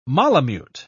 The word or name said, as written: malamute